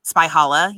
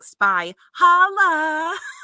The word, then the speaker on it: Spychala: It looks like Spychala. – It does. Yeah, and it makes sense because, you know, I was Jewish for a long time, so it's like challah bread. Yeah. – Yeah. – Yes. – I will actually get that now. – That's actually when I asked her how she pronounced it. That's how she described it. She was like, it's like spy holla.